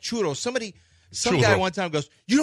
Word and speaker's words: churro: Like, it's churro. – But churro. It's two R. Yeah, a double R. But churro is the only way I know how to say it. It's a Spanish word.